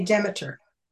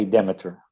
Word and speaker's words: Demeter: How do you say Demeter? – I say Demeter. – Okay, Demeter, sorry.